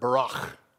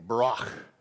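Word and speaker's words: barach (ברך): You got to say it with me, barach. And if you're going to say it properly, you got to understand there's like a kernel of popcorn stuck in the back of your mouth at the end. Barach.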